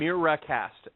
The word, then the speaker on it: Miracast: And it's Miracast, not Miracast. M-I-R-A-C-A-S-T.